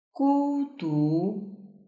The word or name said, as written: gudu